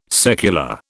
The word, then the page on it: secular: British English